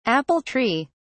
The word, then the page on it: apple tree: British English